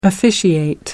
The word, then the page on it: officiate: American English